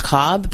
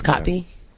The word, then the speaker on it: Kotb: How do you pronounce her last name? Kotby? Is that how you pronounce it? – I think Hoda. Yeah, anyway, that's what I always thought it was. I think it was always mispronounced Cobb, but yeah, it's Kotby.